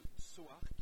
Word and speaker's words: Tsui Hark: Conseil Détective D, donc qui est le nouveau film de Soark, T-S-U-I-H-A-R-K, je prononce l'alphabet parce que en mandarin ça se dit Tsuke, donc ça se prononce pas du tout comme ça s'écrit.